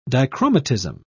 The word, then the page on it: dichromatism: British English